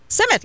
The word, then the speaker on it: Simmet: It's Simmet like symmetry. – Simmet like symmetry.